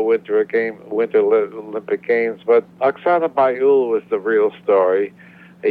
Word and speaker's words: Bayul: I'm not even sure I'm pronouncing the name right. – Oksana Bayul.